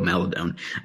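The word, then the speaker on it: Maledon: So we always said Maladon also, but we found out it is Teo Maladon.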